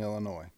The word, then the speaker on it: Illinois: And do you not know the S is silent in Illinois? – Right.